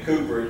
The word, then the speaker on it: Cowper: And if you don't know about that man, his name was William Cooper. It looks like Cowper, but they pronounce it Cooper over in England.